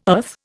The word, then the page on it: earth: Indian